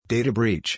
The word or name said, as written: Data Breach